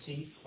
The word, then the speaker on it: Teaf: My name is Christopher Teef. My last name is spelled T-E-A-F, and I'm here from Hazardous Chemicals and Waste Research in Tallahassee.